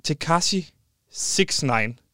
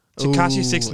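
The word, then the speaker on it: Tekashi 6ix9ine: Tekashi 69. – Tekashi 69.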